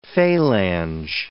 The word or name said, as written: phalange